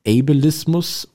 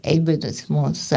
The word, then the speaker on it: Ableismus: Sagt man jetzt Ableismus oder spricht man es deutsch aus? Ableismus? – Ich glaube, man kann beides sagen. Ursprünglich kommt es halt aus dem Englischen, weshalb viele eben Ableismus sagen.